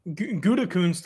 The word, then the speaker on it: Gutekunst: Gutekunst. – Gutekunst.